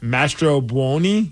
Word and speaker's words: Mastrobuoni: Man, I don't know how to pronounce it. Mastrobuoni? – Yes. – Yeah, okay. – Nailed it.